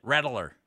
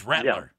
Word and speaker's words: Rattler: But you did three syllables on Rattler. – Rattler. It's Rattler. – Yeah. It's Rattler.